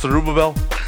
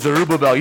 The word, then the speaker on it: Zerubbabel: Do you think I don't know how to pronounce Zerubbabel? I know it's a tongue twister, and most people don't get that. But Zerubbabel, you know the only reason I know how to say that word? – Because you practiced it.